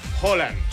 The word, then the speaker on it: Haaland: ¿Se pronuncia Haaland o Holland o Hulan, ¿no? O Hulan o... Bueno, Alfredo González, comentarista de Fórmula Deportes, ¿cómo se pronuncia? – Holland. – Holland. – Holland. – Es con O.